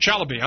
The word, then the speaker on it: Chalabi: Democrats have accused Feith of manipulating information from sources, including discredited Iraqi politician Ahmed Shalabi, or Chalabi, I'm sorry. I always get his name wrong, Ahmed Shalabi.